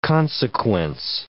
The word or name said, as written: consequence